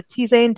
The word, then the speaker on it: tisane: And like a tisane. Is it a tisane? Tisane? I don't know how to pronounce it. Tisane. – Yep.